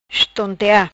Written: Estontea

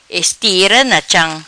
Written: Estira na cham

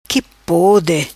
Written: qui pode

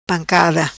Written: Pancada

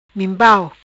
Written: Minpau

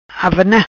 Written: Avana

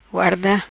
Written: Guarda

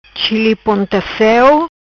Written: Chili ponta ceu

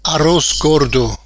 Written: Arroz Gordo

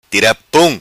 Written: Tira Pum

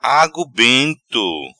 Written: Agu bento

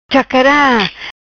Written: Chacara

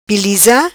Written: Piliza

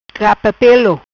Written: Capa Pelo

